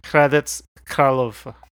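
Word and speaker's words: Hradec Králové: That's the city, so it's the Philharmonic of, and I looked up the pronunciation, and it's more like Hradec Králov.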